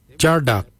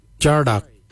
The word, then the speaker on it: djardag: Jindal is spelled D-J-E-N-D-A-L, Jindal. Then we'll look at Jardak. Jardak is grey. Jardak. Jardak is spelled D-J-A-R-D-A-G, Jardak, grey. We'll now look at the word for brown, jittery. Jittery is spelled D-J-E-R-D-A-R-I, jittery, brown, jittery.